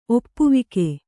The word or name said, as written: ಒಪ್ಪವಾಗು